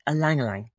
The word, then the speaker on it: Ylang ylang: The fourth oil I used in the blend, is ylang ylang. Ylang ylang, pronounced E, but spelled Y. So the spelling is Y-L-A-N-G. Twice.